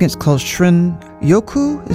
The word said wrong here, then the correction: Shinrin-yoku: Is that how you pronounce it? The Japanese term for wood air bathing. – Shinrin-yoku.